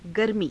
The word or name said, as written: garmii